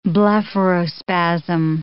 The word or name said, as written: blepharospasm